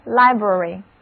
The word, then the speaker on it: library: 注意字母 I 在这里发它的本音 I，而字母 A 的读音是短的 N。短语 Public Library Public library 就是公共图书馆。